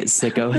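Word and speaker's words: SICO: Oh, okay. It's pronounced Sicko.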